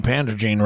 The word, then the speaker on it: Pandrogyne: Well, I've been saying pandrogyne wrong. Pandrogyne. And I've been saying Gysin wrong.